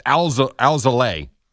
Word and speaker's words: Alzolay: And then the last one, because they mentioned this guy on the broadcast, his name is Adbert Alzolay. He's on the Cubs. Alzolay. Go. – Alzolay, you say? – Yes. Alzolay.